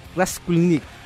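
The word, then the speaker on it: Raskulinecz: discúlpenme la pronunciación porque esta gente que tiene esto apellido, no sé, como chico o algo así, se llama Nick Raskulinich. No sé si Alejandra se atreve a pronunciarlo mejor. – No, yo apruebo tu fonética.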